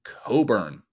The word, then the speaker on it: Cockburn: The name is spelled, for those who don't have this image in front of them like I do, C-O-C-K-B-U-R-N. Yes, our neighbors to the north pronounce that as Coburn, and that is why I am not allowed to wear it in public.